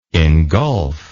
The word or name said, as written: ingulf